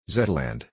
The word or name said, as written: Zeteland